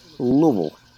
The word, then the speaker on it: Louisville: Like, you just have, it just has to mumble out of your mouth, like one syllable, just like Louisville.